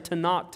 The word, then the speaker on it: Tanakh: When you say that K there in the Hebrew, you got to act like you're harking something up from the back of your neck, okay? That's how they tell you to pronounce it in seminary. So y'all can try that with me. We're going to say Tanakh together. But when you get to that K, I want you